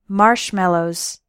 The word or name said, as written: marshmallows